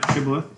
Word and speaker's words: Shibboleth: So he's playing Shibboleth, right? Am I pronouncing that correct? – That's right. Yeah, Shibboleth, Sean Greer.